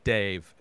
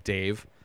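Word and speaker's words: Dev: When I kept hearing them say Dave is this god, and the sequel, part two, is Dave, and it's D-E-V, pronounced by everybody in this movie Dave.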